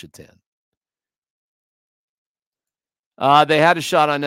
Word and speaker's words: Satan: The one guy, when I worked for the Buffalo Sabres, his last name was spelled S-A-T-A-N. Take a shot at that one. How would you pronounce S-A-T-A-N? Mm-hmm. Yeah. Shatan. Not Satan. Was not pronounced Satan. It was Shatan.